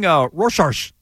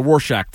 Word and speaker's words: Rorschach: No. Shack. It looks like it. Rorschach. – Is that how you say it? Yeah, he's also a famous character in Watchmen. The Rorschach test.